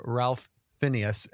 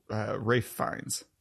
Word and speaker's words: Ralph Fiennes: Christoph Waltz as Blofeld and Ralph Fiennes as M. – Rafe Fiennes. – Yeah. Rafe Fiennes. What? What? – Rafe Fiennes. – Rafe. – Yep. – That's how you spell that? – Yep.